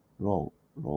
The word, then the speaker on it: roe: えっ、と、フィッシュはフィッシュでいいんですけど、で、ロアっていうのが ROE で、ロウ、ロウ、ロウと読むんですね。